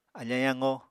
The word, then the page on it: afañ yaqo: Apatani